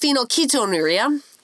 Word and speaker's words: phenylketonuria: It's phenylketonuria.